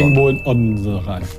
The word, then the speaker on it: Odense: Einzige Stadt, die ich was in Dänemark kenne, weil ich sie immer falsch ausspreche, ist Odense. Es wird Odense geschrieben. Und dann blamiert man sich immer, wenn man Odense sagt. Also Odense.